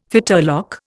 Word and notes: fetterlock: Indian

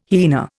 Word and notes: hyena: Indian

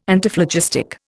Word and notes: Antiphlogistic: Indian

